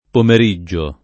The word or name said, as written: pomeriggio